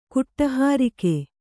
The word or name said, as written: ಕುಟಹಾರಿಕೆ